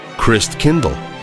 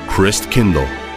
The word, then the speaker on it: Christkindl: How do you say Christ child in Luther's German language? Christkindl. That's right, Christkindl. Well, Luther's attempts failed, but Christkindl got swallowed up by Christmas and got transformed into Kris Kringle.